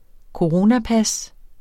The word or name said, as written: coronapas